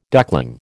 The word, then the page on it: duckling: American English